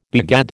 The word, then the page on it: begad: American English